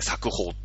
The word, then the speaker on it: 作法: 作に封じるって書いて作法って読むんだけど、作法体制に入れちゃうんだ。